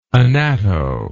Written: anatto

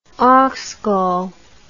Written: oxgall

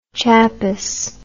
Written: chapess

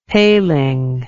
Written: paling

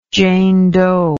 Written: jane doe

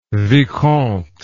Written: vicomte

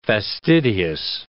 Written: fastidious

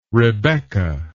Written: rebecca

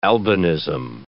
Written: albinism

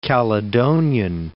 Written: Caledonian

